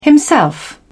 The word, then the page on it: himself: British English